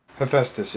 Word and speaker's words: Hephaestus: Hephaestus. Here. – Is that how you say it? – Hephaestus, yes.